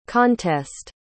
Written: contest

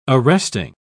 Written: arresting

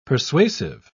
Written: persuasive

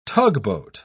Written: tugboat

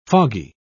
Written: foggy

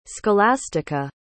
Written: Scholastica

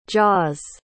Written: jaws

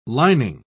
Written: lining

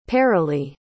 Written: paralee

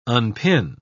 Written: unpin

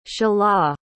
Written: shelah

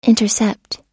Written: intercept

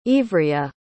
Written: Ivria

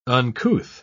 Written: uncouth

